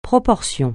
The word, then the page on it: proportion: francais